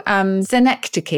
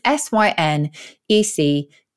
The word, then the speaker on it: synecdoche: And it's very close to synecdoche, is how Oxford would tell you to pronounce it. So this is synecdoche, synecdoche.